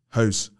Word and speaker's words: Hose: Was it pronounced hose or hose? – Hose. – Hose. – So like a garden hose.